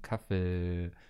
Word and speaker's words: Kaffee: Es ist ein Doppel-E, das heißt, es muss lang ausgesprochen werden. Kaffee.